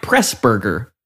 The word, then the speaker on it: Pressburger: Oh, I'm going to butcher his last name, I don't think. Emmerich Pressburger. That's it.